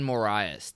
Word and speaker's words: Moraes: Morales is how you say his name? – Marlon Moraes.